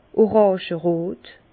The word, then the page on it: oràngerot: Bas-Rhin; Herrlisheim